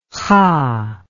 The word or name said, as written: kha